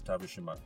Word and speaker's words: Tavershima: And so my name is spelled TAVERSHIMA. That's Tavishima.